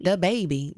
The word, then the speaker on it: DaBaby: How do I say his name? DaBaby. – DaBaby. Just DaBaby. – DaBaby. DaBaby. – DaBaby. DaBaby. – DaBaby. He's country, so it makes me say it with a country accent.